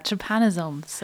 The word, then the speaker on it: trypanosomes: Today we are talking to the Group from the European Molecular Biology Laboratory about trypanosomes. Yes. Is that correct? – Yeah, trypanosomes.